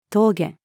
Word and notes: 峠: female

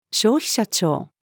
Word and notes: 消費者庁: female

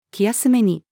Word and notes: 気休めに: female

